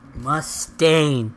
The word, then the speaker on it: Mustaine: Mustaine, thank you.